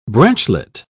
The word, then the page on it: branchlet: British English